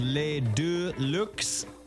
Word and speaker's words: Les Deux Lux: If you want to look them up, it's spelled L-E-S. And then all one word, Deux Lux, like deluxe, but French, I guess.